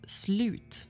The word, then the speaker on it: slut: So it's actually pronounced slut. – What? – Slut. Oh, not slut. – I've been saying spelled S-L-U-T.